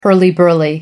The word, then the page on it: hurly burly: American English